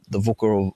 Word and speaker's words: VUCA: We now talk a lot about the volatile, uncertain, complex, ambiguous, or the VUCA or VUCA world, depending on how you want to pronounce it.